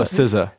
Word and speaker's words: SZA: And then finally, it's Saz, S-C-A. I don't know how to pronounce that. – Oh, SZA.